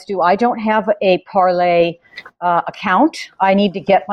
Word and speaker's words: Parler: We're talking about Parler this morning, or Parlay. How do you pronounce that? Je suis Parlay. – What is that? – I think it's Parlay. It's Parlay.